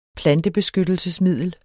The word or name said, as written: plantebeskyttelsesmiddel